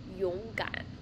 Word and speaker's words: 勇敢: Brave in English, so Mandarin, it pronounced as 勇敢.